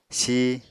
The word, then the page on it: si: Apatani